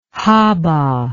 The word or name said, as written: haba